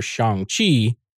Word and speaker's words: Shang-Chi: you had a theory way back when for Shang-Chi. That's right, I said it right.